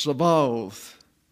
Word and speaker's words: Sabaoth: However, when the holim is absent, and you can see the character there, the B character without the dot in the middle, when the holim is absent, the pronunciation changes to a V. Therefore, the Hebrew title of the Lord is pronounced Sabaoth.